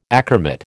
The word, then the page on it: achromat: American English